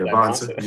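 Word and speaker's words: Dybantsa: DiBonsa, is that how you say it? – DiBonsa. Yeah.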